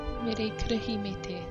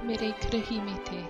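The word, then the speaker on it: Meri Kirihimete: Mere kurihimete.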